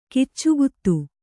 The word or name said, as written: ಕಿಚ್ಚುಗುತ್ತು